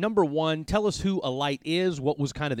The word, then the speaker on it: Alight: Am I saying that right? A-L-I-G-H-T? Alight? Is that? – Yeah, just like Alight. I mean, just the word Alight.